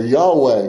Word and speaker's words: Yahweh: Isaiah 26, Jehovah, or Yahweh would be the Hebrew pronunciation of that.